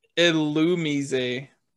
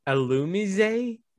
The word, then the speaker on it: Illumise: Apparently, it's Illumise. – Illumise.